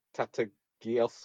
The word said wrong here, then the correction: Tatasciore: Oh, Tatasciore, yeah.